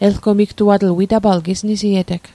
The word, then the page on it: elgomigtuatl: Mi'gmaq